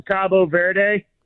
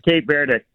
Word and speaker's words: Cabo Verde: Either way, you said it right. That's the it's kind of like Turkey, Turkey. – Yeah. – They in Spanish, it's Cabo Verde. – Cabo Verde. – Cabo San Lucas or whatever in Mexico. Cabo Verde. But okay. The English pronunciation is Cape Verde.